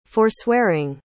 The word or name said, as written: forswearing